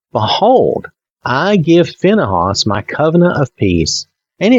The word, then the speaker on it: Phinehas: Then the Lord spoke to Moses, saying, Phinehas, now you could say Phinehas, I guess, or almost Phineas. That's kind of what it looks like, but I'm pronouncing it more Phinehas.